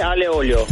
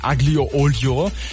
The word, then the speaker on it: aglio e olio: Und das ist Spaghetti aglio e olio. – Nimm das, Thiago. Aglio e olio spricht man es aus, nicht aglio olio.